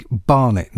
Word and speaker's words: Barnett: Courtney Barnett, not Courtney Bennett,